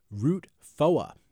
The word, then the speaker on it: Route 4: Yeah, and in this story, Route 4 is actually pronounced Route Foa. That's actually the correct pronunciation of a beer they have on tap at Blasty Bow Brewing Company in Epsom.